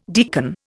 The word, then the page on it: deacon: Indian